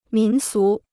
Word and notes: 民俗: mín sú

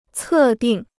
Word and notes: 测定: cè dìng

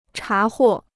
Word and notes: 查获: chá huò

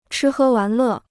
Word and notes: 吃喝玩乐: chī hē wán lè

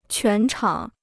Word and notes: 全场: quán chǎng

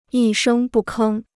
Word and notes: 一声不吭: yī shēng bù kēng

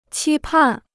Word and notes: 期盼: qī pàn

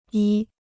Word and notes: 一: yī

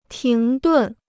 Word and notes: 停顿: tíng dùn

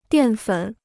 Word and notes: 淀粉: diàn fěn

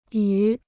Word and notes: 与: yù

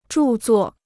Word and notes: 著作: zhù zuò

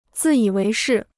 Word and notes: 自以为是: zì yǐ wéi shì

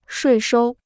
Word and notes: 税收: shuì shōu

